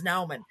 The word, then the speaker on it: Naumann: I asked if you wanted to be called Jeff or Jeffrey, but then I just realized, wait a minute, it could be Naumann or Naumann. No, it's Naumann. I'm sorry I mispronounced it.